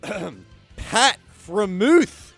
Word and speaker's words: Freiermuth: Pat Freiermuth.